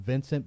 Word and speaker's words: Wincent: It might be called Vincent, but it's spelled Wincent, Vincent drumsticks.